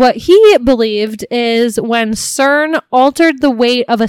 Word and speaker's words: CERN: is the theory that CERN, I think is how it's pronounced. – It's like an acronym, CERN.